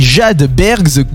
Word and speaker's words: Jade Bergs: Ça s'écrit jadebergs, donc comme iceberg, J-A-D-E espace B-E RGS Jade Bergs.